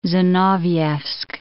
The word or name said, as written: Zinovievsk